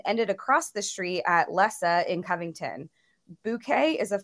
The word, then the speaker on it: Lisse: The highlight for me was the announcement of Bouquet in Lisse, which is spelled Lisse, L-I-S-S-E, but it's pronounced Lisse, like Melissa.